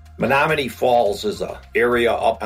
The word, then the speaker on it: Menominee: Menominee. It is spelled exactly as it sounds.